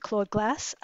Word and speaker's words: Claude Glass: Would you like to read Claude Glass or Cloud Glass? How do I pronounce that? Maybe tell us a little bit what that means. – Sure. I always pronounce it Claude Glass.